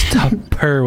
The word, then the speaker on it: Tupperware: Tubber? Like with a B? – Yeah, with a B. – Bro. – Bro, I did not know it was Tupper with a P.